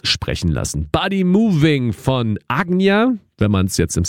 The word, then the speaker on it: Ajna: Ajna wird es eigentlich im Sanskrit ausgesprochen. Die meisten würden wahrscheinlich Ajna zu sagen. A-J-N-A geschrieben, ist aber gleichzeitig auch ein belgischer Produzent. A-J-N-A geschrieben, der sich hier mit Mischa zusammengetan hat.